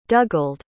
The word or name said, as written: dugald